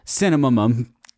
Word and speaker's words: Cinnamomum: Can you spell that N part? So is it spelled like... – It's cinna. – Cinna. Mm-hmm. – Cinnamomum. – Mum-mum. – C-I-N-N-A-M-O-M-U-M. It sounds like somebody just taking a run at the word cinnamon and just like hitting the hurdle with both knees at the same time and just eating shit right into the pavement.